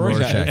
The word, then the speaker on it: Rorschach: Am I saying that correctly? – Rorschach? – Say it again. – Rorschach? – Rorschach. – Rorschach. – Okay, yeah. Rorschach.